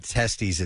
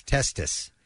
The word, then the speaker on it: testis: It's not testes. It's testis. Oh. That's a singular nut.